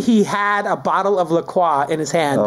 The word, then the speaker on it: La Croix: I say La Croix, like the French might say it.